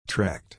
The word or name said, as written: trekked